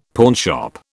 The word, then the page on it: pawnshop: British English